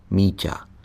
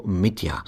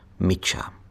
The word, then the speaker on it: Míťa: Käthe několikrát zkusila neúspěšně vyslovit jeho podivuhodné jméno – Míťa. Znělo to jako Mitja. Správná, měkká výslovnost slovansky mazlivé zdrobněliny jména Dimitrij je v němčině prakticky nemožná.